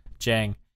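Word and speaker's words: Dieng: take back Dang, and they keep, I can never say his name, Gorgie Dang? – Jang.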